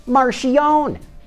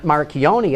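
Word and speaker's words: Marchionne: Speaking of losing money on electric cars, Fiat Chrysler head honcho Sergio Marchione, that's right, he pronounces it Marchione, not Marchione as I've been saying it all along, and I only know this because I asked him myself yesterday.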